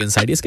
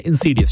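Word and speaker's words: Insidious: Well, अलग अलग लोग अलग अलग तरीके से प्रोनाउंस करते हैं, कुछ लोग इनसाइडियस कहते हैं, कुछ लोग जैसे अमेरिका में इनसीडियस चलता है।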